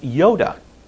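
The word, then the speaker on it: Yoda (ιώτα): And it's pronounced Yoda, exactly like that little guy in Star Wars.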